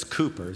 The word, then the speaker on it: Cowper: And so William Cooper, it's actually spelled C-O-W-E-R or C-O-W-P-E-R, but it's Cooper is how it's pronounced.